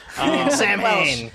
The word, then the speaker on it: Samhain: That is Samhain. – Whoa. – Pronounced Samhain Gaelic. Gaelic.